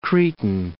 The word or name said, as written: Cretan